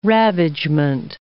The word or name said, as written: ravagement